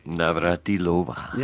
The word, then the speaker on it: Navratilova: Navratilova, is that how it is? – Navratilova.